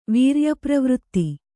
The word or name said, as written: ವೀರ್ಯಪ್ರವೃತ್ತಿ